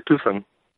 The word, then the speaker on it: Tucson: Que ellos lo pronuncian diferente, ¿verdad? Es algo así como Tucson, ¿no? – O algo así. Sí, Tucson. Sí, le quitan la, la C. – Sí, le quitan la C en medio, ¿no?